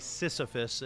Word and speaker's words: Sisyphus: So for those of you who aren't familiar with Sisyphus, I did say Sisyphus. It's a Greek mythology, so we're clear on the pronunciation there.